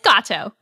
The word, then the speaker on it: Gato: Yes, it's Gato.